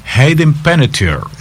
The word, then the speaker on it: Hayden Panettiere: ¿Cómo es que se pronuncia que tú dijiste? – Hayden Panettiere.